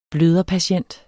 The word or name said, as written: bløderpatient